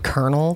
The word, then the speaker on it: colonel: So he was just like, why is there no R in the word colonel? That drives me insane. – I know. Isn't it weird how it's spelled and how it's pronounced? – Colonel, but then it's colonel.